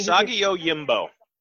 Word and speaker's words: Usagi Yojimbo: Usagi Yojimbo.